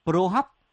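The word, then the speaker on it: prahok: Tiếng Khmer, cái từ mắm nó có nghĩa là đọc là pro hóc. Đấy, thì chúng ta gọi chệch ra là bò hóc.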